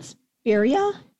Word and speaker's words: spirea: Pink, how do you pronounce that? Spirea? – Yep.